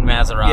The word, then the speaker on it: Matarazzo: Matarazzo. – Yeah, Gaten Matarazzo.